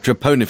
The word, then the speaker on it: troponin: Have I pronounced it right? Troponin. – Troponin, forgive me.